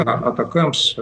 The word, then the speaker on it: ATACMS: некоторые сокращают его как Атакамс, ну, правильно, наверное, произносить ATACMS, потому что для нас это очень тяжело. – Ну, Атакамс, господи. – Да, Атакамс, Атакамс.